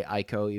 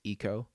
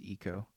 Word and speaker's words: ICO: I also say Aiko, even though apparently it's Eco. – Really? – Yeah, it is. That's from the developers. It's Eco. But everyone I've ever known pronounced it Aiko.